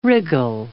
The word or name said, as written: wriggle